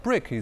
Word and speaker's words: BRIC: 因为这 4个国家的英文首字母组成了 BRIC 一词，发音和英文砖块非常相似，所以金砖 4国应运而生。 2011年，南非正式加入。金砖 4国因此改称为金砖国家，英文也变为 BRICS。